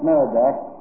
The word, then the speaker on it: Merodach: Only in Hebrew it's pronounced Merodach, and in Babylonian Marduk, same God, same order.